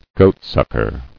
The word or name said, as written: Goatsucker